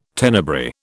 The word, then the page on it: Tenebrae: British English